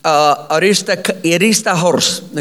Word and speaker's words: Aristarchus: Aristarchus, okay, and that's how you pronounce it in Greek.